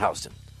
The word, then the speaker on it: Houston: It's pronounced Houston.